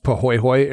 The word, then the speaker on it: pahoehoe: It's spelled P-A-H-O-E-H-O-E, and it's pronounced Pohoihoi, and it's a flow texture.